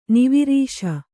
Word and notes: ನವರಸ: kannada

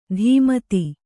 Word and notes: ಧೀಮತಿ: dhī mati

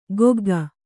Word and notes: ಕೊಗ್ಗ: kogga; kannada

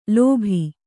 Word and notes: ಲೋಭ: lōbha

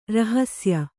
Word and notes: ರಹಸಿಯ: rahasiya

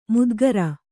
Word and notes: ಮುದ್ಗರ: mudgara

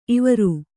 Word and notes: ಇವರು: ivaru; Kannada

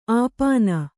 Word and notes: ಅಪಿನಿ: apini

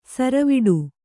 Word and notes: ಸರವಟ್ಟಿ: kannada

